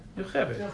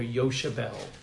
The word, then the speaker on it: Yocheved: They say in the movie the actors couldn't pronounce her name, so they called her Yoshebel. In the movie, they couldn't say, they wanted to say Yocheved, so they couldn't say the ch sound, so they changed her name in the movie to Yoshebel.